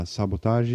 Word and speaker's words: Sabotage: Sabotage is the correct pronunciation, even though it's a English word.